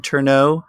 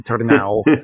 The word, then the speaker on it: Turnau: I answer to both. I was raised with Ted Turnau. But in the Czech Republic, if I say that, they misspell my name, so I have to go Ted Turnau.